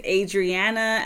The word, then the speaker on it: Adriana: My name is Adriana, emphasis on the D and the R.